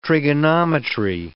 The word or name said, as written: trigonometry